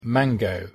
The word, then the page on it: mango: English